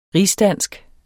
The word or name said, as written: rigsdansk